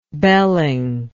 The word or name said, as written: belling